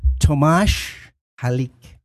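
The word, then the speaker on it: Tomáš Halík: And that's begun by teaching people the proper way to pronounce his name. – Tomasz Halick.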